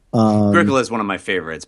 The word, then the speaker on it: Agricola: Yeah, Agricola.